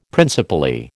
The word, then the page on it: principally: American English